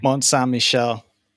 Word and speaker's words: Mont Saint-Michel: Mont Saint-Michel.